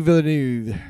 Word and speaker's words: Villeneuve: It's Denis Villeneuve.